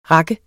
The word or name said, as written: rak